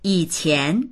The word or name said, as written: yi3qian2